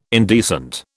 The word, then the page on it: indecent: British English